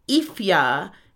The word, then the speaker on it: Aoife: Nine is Ella, ten is Mia, eleven is Lucy, twelve is Emma, thirteen Lily, fourteen Olivia, fifteen Chloe, sixteen Ifya, and that's spelled A O I F E.